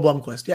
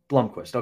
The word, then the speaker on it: Blomqvist: The biggest thing for Blomqvist, did I say that right? Blomqvist? – Joel Blomqvist, yeah. – Blomqvist, okay.